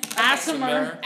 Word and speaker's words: Aasimar: A-A-S-I-M-A-R. – M-A-R. Yeah, that, there's no other way to pronounce that. – Seriously. – Asimer.